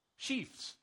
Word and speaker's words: sheaves: He says, we're going to let her go to when we've harvested and we got the sheaths. How do you pronounce that word? Farmers? Sheaves. Thank you. Sheaves. Sheaves. Let her pick from there and drop extra on the floor.